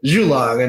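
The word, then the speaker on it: Zhulong: And then we had Zulong in the Buried Goddess saga, and Luke Daniels read it Zulong. And so that was when we both learned ZH is, that's what it becomes, because apparently that's how it's phonetically supposed to be pronounced.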